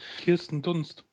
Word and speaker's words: Kirsten Dunst: Kirsten Dunst.